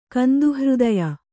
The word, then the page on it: ಕಂದುಹೃದಯ: kanduhřdaya